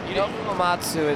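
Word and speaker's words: Nobuo Uematsu: Nobu Uematsu is how you pronounce it.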